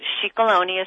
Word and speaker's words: Scicolone: And Scicolone is spelled S-C-I-C-O-L-O-N-E.